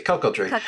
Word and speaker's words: cuckoldry: I also know that this is one of the most searched terms on porn sites, and is it cucking? Cuckold? How do you pronounce it? – Cuckoldry.